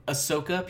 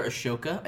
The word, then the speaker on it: Ahsoka: Ahsoka, people call her Ashoka. I don't know why. – It's because the H is there.